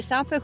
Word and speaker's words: SUP: SUP o SAP? – Allora, c'è chi dice SAP, comunque deriva dall'acronimo Stand Up Paddle americano, quindi... – A seconda della pronuncia, se lo vuoi pronunciare all'italiana.